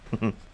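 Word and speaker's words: Québécois: Quebecois? Did I? You can't nod. It's a podcast. – Quebecois. – Quebecois.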